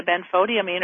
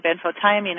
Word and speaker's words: benfotiamine: It has, as you said, the benfotiamine or benfotiamine. I've heard it pronounced both ways. – I say benfontamine.